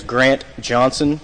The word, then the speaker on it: Grant Johnson: My name is Grant Johnson, spelled G-R-A-N-T-J-O-H-N-S-O-N.